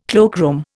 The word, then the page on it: cloakroom: Indian